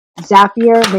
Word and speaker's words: Zapier: Zapier makes you happier. Just that is the way to remember it. You'll never say it wrong again.